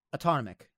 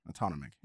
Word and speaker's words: autonomic: Autonomic. – Autonomic.